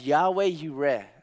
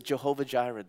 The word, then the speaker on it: Yahweh Yireh: And I think about when the Bible says the Lord provides, Yahweh Yireh, some people pronounce it Jehovah Jireh, the Lord provides.